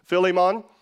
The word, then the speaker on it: Philemon: Philemon, which is like the American common pronunciation. If you really want to get technical, technically it's Philemon, but we're going to go with Philemon because that's what we're just used to saying here in the U.S.